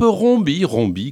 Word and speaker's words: Rombi: le dénommé Philippe Rombi,